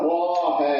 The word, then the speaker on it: طه: هي طه بس يمكن تنطقيها طه بالالف والهاء بعديها الف، بس ما بتكتبيش لا الالف الاولى ولا الثانيه، بتكتبيها زي ما انا كاتبها كده.